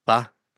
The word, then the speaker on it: pho: I would, you know, you kind of get used to that, that flavor, but, I probably would not order the pho. Am I saying it right? Pho. See, I did it wrong. Pho.